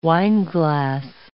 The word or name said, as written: wineglass